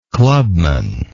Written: clubmanship

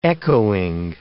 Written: echoing

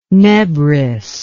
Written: nebris

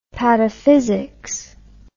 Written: pataphysics

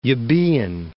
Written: Euboean

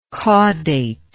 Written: caudation

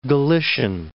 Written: Galician

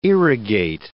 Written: irrigate

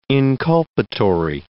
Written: inculpatory

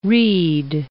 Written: rede

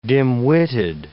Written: dim-witted

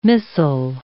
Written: missile

